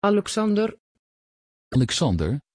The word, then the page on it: Alexander: Dutch